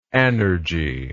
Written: anergy

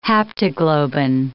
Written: haptoglobin

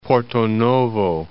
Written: Porto-Novo

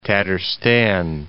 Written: Tatarstan

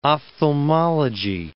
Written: ophthalmology

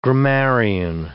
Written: grammarian

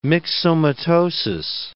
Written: myxomatosis

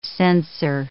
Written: censer

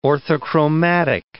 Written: orthochromatic

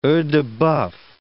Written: oeils-de-boeuf

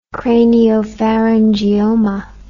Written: craniopharyngioma

